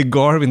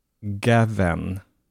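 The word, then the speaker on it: Gawain: Han, om han säger Garwin, då får det bli Garwin. Så att det finns åtminstone, även i filmen så finns det minst en 3-4 olika alternativa uttal. Det vanligaste är det jag tolkar som Sir Gavin, ungefär. Gavin. – Ja, alltså, för jag trodde lite så här, som det står, alltså så här Gavin, men ja. Du menar lite mer Gavin.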